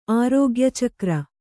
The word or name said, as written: ಆರೋಗ್ಯಚಕ್ರ